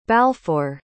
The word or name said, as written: balfour